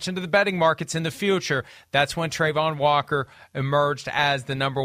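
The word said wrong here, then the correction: Travon: I'm saying his first name wrong. As I learned last night, it's Trevon, not Trayvon.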